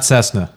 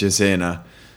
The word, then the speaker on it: Cesena: It's not Cessna. – That's right. That's right. There's three ways to say that word, and you're saying it right.